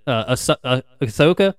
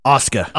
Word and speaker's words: Asuka: Well, how you pronounce it? Ahsoka? No. – Asuka.